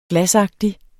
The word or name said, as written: glasagtig